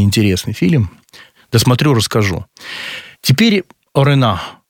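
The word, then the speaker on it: Orano: Ее все почему-то называют Орена, но Орена, ударение, последний слог.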